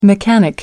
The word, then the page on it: mechanic: American English